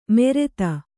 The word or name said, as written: ಮೆರೆತ